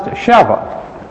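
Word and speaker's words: Sheva (שבע): In Hebrew, the word is spelled Sheba and pronounced Sheva.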